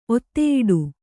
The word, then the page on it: ಒತ್ತೆಯಿಡು: Kannada